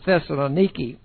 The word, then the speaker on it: Thessaloniki: I was in Thessalonica. They pronounce it Thessaloniki.